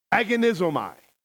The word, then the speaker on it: agonizomai: Now, let me transliterate that Greek word. In other words, let me pronounce it as it would be written in English with the same pronunciation in the original. Agonizomai.